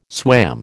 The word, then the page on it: swam: American English